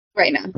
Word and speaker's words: Rhaenyra: is Baela. I not Baela. Rhaenyra. – Reina, I said it wrong first.